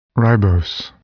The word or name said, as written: ribose